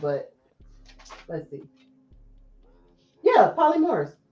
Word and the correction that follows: polyamorous: Yeah. – So polyamorous.